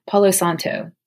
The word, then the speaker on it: Palo Santo: And I believe I'm saying that right. Palo Santo. That's how you say it.